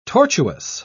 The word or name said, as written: tortuous